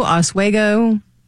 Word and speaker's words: Oswego: No, how do you say it? Oswego? – Oswego. – Oswego, okay.